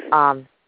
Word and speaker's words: Zendaya: I also know that you've partnered with artist Jordan Newitt, as well as, of course, touring with the Disney star Zendaya. Is that how that's pronounced? I wasn't quite sure how to say that. – Zendaya.